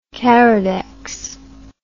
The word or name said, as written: Caridex